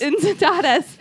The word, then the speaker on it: Incitatus: I don't know how to pronounce it. – I like Incitatus. – Incitatus. okay, so according to legend, it's Incitatus.